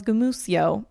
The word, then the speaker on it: Gumucio: Jo, det är Greg Gumucio, som tydligen uttalas Gumucio, men det är svårt för min hjärna att ta in den informationen.